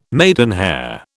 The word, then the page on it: maidenhair: British English